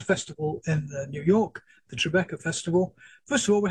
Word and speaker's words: Tribeca: Tribeca.